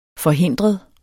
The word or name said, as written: forhindret